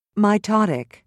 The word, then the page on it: mitotic: British English